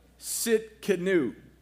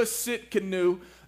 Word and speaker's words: Tsidkenu: The T is silent. Can we put that graphic up? There it is. Okay. So this is how you spell the name Jehovah Tsidkenu. That T is silent there.